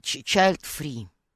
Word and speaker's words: child-free: child-free. Я правильно произнесла? – Ну да, да. Это правильно. так произносится, да. да, child free.